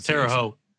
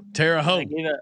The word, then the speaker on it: Terre Haute: I think it's Terre Haute. – Terre Haute. – I gave out two options. One of them's got to be right, right? – It's like Ho. Like it's Terre Haute.